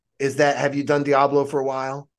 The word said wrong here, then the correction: Diabolo: So it's Diabolo. – Yeah, I was saying it wrong.